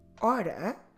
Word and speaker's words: hora: In Portuguese, the H is completely silent. So we did already hábito, helicóptero, hipermercado, a hypermarket, hipermercado, hora, hour.